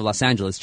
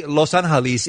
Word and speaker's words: Los Angeles: but when you talk about pronouncing it correctly, though, I mean, you can say Los Angeles is the correct pronunciation. But in the United States, the city is really now called Los Angeles.